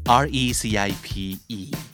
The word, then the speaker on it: recipe: recipe R E C I P E อ่านว่า recipe